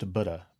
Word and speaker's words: Buddha: Take the very word Buddha itself, more properly pronounced Buddha, but it's proving hard to unlearn a lifetime habit.